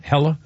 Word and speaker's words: Hela: It's spelled H-E-L-A. So is it Hela? – Ella. – Ella? Hela? Okay. – Hela. – Yes, thank you.